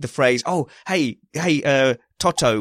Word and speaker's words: Toto: Toto.